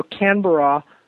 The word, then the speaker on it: Canberra: I stood a good chunk of the time in Sydney, and then I went to, I'm probably saying it wrong, Canberra, where Canberra.